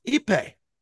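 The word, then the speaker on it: Ippei: from Ipe. That's how you pronounce it.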